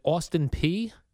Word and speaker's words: Austin Peay: He's a defensive back from, is it Austin Peay? – Yeah. – That's how you say it? – Mm-hmm. – Austin Peay.